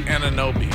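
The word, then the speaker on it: Anunoby: Ananobi? – Ananobi.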